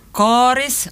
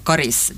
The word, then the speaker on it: Karis: Men rekommendationen att säga Karis med långt A, det har funnits sedan början av 1960-talet. Men det här dialektala uttalet med kort A, Karis, det är äldre.